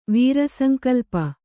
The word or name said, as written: ವೀರಸಂಕಲ್ಪ